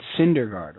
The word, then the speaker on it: Syndergaard: But Noah, I have always called him Noah Snydergaard, and I have to try to fix that. – It's Syndergaard, right? – Syndergaard. – Yeah. – Noah Syndergaard.